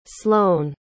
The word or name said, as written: sloan